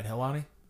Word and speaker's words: Helwani: I pronounced that right, Helwani.